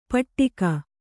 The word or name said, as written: ಪಟ್ಟಿಕ